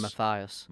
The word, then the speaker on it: Matthias: Is that how you say it? Or Matthias? – I would say Matthias.